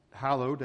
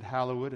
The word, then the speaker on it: hallowed: when if you had an ED ending on a word, you pronounced it. And so for centuries, people were pronouncing the EDs on the end of the word. They wouldn't say hallowed, they would have said hallowed, and so I think that's probably the primary way that we come down saying hallowed be thy name.